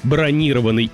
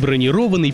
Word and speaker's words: бронированный: Слова «бронированный» и «бронированный» пишутся одинаково, но от ударения так много зависит. И здесь мы запоминаем, бронировать значит зарезервировать, например, номер, а бронировать – покрыть бронью или защитой, например, бронированный сейф.